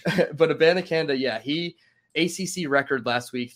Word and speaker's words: Abanikanda: Abanikanda. That's how you say it.